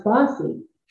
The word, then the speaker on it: BAWSI: So we started with just an idea, and somebody very quickly said, Bay Area Women's Sports Initiative, the acronym BAWSI, would be pronounced Bossy.